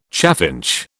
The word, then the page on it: chaffinch: British English